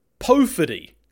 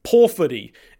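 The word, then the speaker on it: Pōwhiri: That is in fact not the correct pronunciation. The correct pronunciation is Powhiri, in keeping with the O should make an O sound, not an O sound, as is A, E, I, O, U, which is what the Māori vowel sounds sound like.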